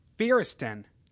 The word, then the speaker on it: Feresten: And we are sitting with Spike Feresten.